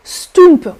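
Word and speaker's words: Stoemp: Euh, le mot provient d'un dialecte de Bruxelles, Stump on prononce stump et c'est un mélange de purée de pommes de terre avec d'autres légumes.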